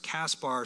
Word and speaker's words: Caspar: That says Gaspar, but it's usually pronounced Caspar.